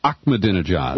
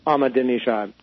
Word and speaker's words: Ahmadinejad: And help me, I thought it was Ahmadinejad. – Well, I have heard some Iranians pronounce it that way, but it's Ahmadinejad.